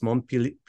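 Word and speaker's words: Montpelier: Now you're in a town I still can't pronounce, Montpellier. – Yeah, that's perfect.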